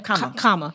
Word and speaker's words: Kgama: Kgama. In English, a G is silent. – Gotcha. – And in Setswana, it's a K.